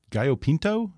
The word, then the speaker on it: Gallo Pinto: Yeah, I think you say that they have the healthiest breakfast in the world, something called, uh, I, think, I hope I have this pronounced right, Gallo, Pin Gallo Pinto? – That's is that right. right? Yes, yes.